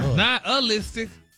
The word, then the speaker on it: nihilistic: But you actually pronounced it correctly the last time you said it. – Really? Nihilistic? – Wow. – Stop putting the ooh in there.